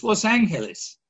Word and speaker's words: Los Angeles: Note Los Angeles, Los Angeles, and I checked with our resident Spanish experts on how this poor gringo is to pronounce it. It's Los Angeles.